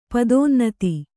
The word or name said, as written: ಪದೋನ್ನತಿ